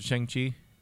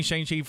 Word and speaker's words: Shang-Chi: Marvel has a kung fu movie called Shang-Chi. – Shang-Chi, okay. – Okay. – I've been saying Shang-Chi for all my life, and so he pronounced it correctly.